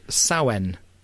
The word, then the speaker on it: Samhain: And halfway between the Maybon Equinox and the Yule Solstice, we have a celebration called Samhain, which is spelt Samhain, and is Irish for summer's end.